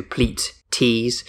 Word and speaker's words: Pleaties: The name is a David Pleat reference, so it's spelt P-L-E-A-T and then double E-S on the end, so Pleat Tees. Bardi, you love a Pleaties t-shirt.